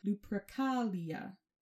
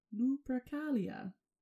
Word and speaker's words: Lupercalia: Well, actually, Lupercalia is a Roman festival, and so that word is Latin, and I had to look up how to say it because I couldn't figure it out, and so Lupercalia. My inflection is probably incorrect.